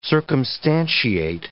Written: circumstantiate

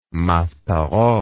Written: Maspero

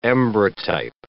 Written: ambrotype

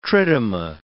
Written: tritoma

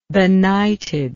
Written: benightedness

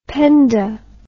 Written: Penda

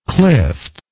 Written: clift

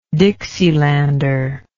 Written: dixielander

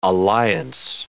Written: alliance

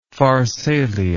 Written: pharsalia

